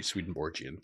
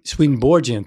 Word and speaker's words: Swedenborgian: It's usually a soft G, Swedenborgian. – Swedenborgian, thank you.